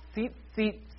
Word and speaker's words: tzitzit: The word for these fringes is tzitzit. I'm going to spell it for you because it's challenging. It's T-Z-I-T. Z-I-T. T-Z-I-T-Z-I-T.